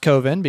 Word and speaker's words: Coven: Shot between 1995 and 1997, the documentary follows filmmaker Mark Borchardt as he attempts to make the short horror film Coven, pronounced Coven because coven sounds too much like oven.